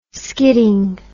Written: skidding

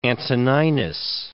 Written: Antoninus

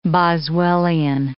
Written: Boswellian

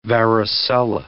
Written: varicella

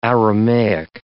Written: Aramaic

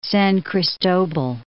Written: San Cristobal

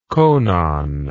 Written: conon

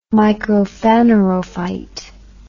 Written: microphanerophyte

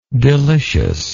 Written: deliciousness

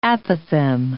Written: apothem

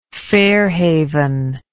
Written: fairhaven

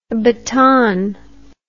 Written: batonne

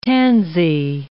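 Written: tant mieux

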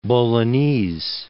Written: Bolognese

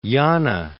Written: Yana

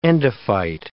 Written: endophyte

